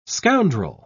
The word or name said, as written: scoundrel